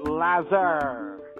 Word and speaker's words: Lazar: No, no, no, no. It's Lazar. It's pronounced Lazar.